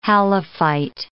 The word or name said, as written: halophyte